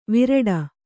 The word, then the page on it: ವಿರಾಟ: kannada